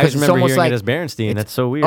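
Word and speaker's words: Berenstain: It's Berenstein. Like Jay Baruchel. – Look, it even. Yeah, it's spelled that way, but is it pronounced that way? – Oh, so it is spelled like B-E-R-E-N. – I think it's pronounced Berenstein Bears. But I understand why people would say Bernstein Bears.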